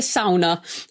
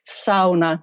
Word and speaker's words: sauna: I have tried over my 35 years here to educate the whole of the UK that it's pronounced sauna, not sauna, but you know, it's a long way to go.